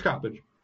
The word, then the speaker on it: Coppedge: I've always called you Bob Coppedge, but somebody recently pronounced your last name Coppedge. – It's Coppedge.